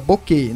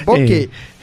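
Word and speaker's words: Bocquet: A Suíça foi a campo com Stuber no gol, Neuri e Boquet na zaga. Boquet, não tem a última sílaba, não confunda com aquela outra palavra. – É Boquet.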